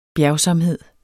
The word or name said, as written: bjergsomhed